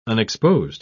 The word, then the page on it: unexposed: British English